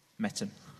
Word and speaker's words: Mettam: Metam. – How do you spell that? – M-E-T-T-A-M.